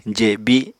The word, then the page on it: jebi: apatani